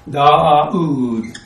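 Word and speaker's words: Daud: So, I think it's D-A-U-D, is that right? Yeah. Da-a-ood. Long A, long U, and that's David.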